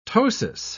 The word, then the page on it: ptosis: British English